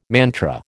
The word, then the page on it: mantra: American English